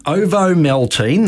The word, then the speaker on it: Ovomaltine: A malted milk drink called Ovomaltine, that's right, how you pronounce it, was released in Switzerland.